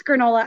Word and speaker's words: Grenola: Am I pronouncing it right, granola? – Yes, Grenola, I am.